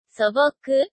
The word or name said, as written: soboku